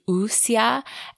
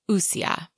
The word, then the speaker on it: Wuxia: So a more correct pronunciation of that would be Wuxia. And I, again, am still not Chinese or anywhere close to that. So I'm probably still a bit far off on the correct pronunciation. But since we were so very far off, I just wanted to break in here and say we're also going to say it wrong again, and apologies for that. But the closer, more correct pronunciation would be something like Wuxia, I think.